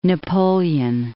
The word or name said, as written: Napoleon I